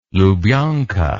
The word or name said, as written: Lubyanka